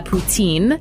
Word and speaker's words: Poutine: And there is a restaurant in France, and they decided their restaurant is called La Maison de la Poutine. And in French, Putin is actually written and pronounced like poutine, exactly like, you know, the signature dish coming out of Quebec.